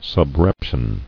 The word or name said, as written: Subreptitious